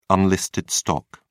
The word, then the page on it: unlisted stock: British English